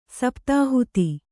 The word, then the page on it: ಸಪ್ತಾಹುತಿ: saptāhuti; kannada